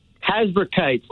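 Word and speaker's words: Hasbrouck Heights: Am I saying that right? Heisberg Heights? – Heisberg Heights, not too far from your old town in Leonia.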